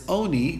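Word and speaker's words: oni (עני): It's written lechem ani. We pronounce it as oni, but it's still spelled with an ayin, and therefore he doesn't darshan that it has to be able to be eaten in mourning.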